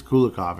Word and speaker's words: Kulikov: I keep saying it wrong. There's no H. There's no H. Why am I saying H? It is Kulikov in this case.